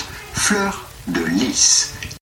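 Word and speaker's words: fleur-de-lis: In French, this is normally pronounced fleur de lis.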